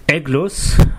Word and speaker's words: Aeglos: Und das Gerät ist ein Pod-System, ähm, das da mit dem schönen Namen Eglus P1 daherkommt. Eglus schreibt man A-E-G-L-O-S und dann P1, Pod-System.